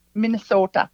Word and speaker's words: Minnesota: Minnesota.